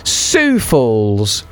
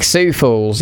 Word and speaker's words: Sioux Falls: Hello to Cole and Steve-O, who are both getting in touch to let me know that it is indeed pronounced Sioux Falls. I think Sioux Falls.